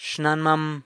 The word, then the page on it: xnanmam: grandmother (e-poss. only)